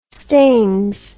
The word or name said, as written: staines